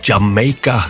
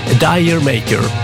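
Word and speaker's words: D'yer Mak'er: Led Zeppelin från Houses of the Holy och Jamaica, som låten heter, eller som du uttalar det, Dire Maker. Fel.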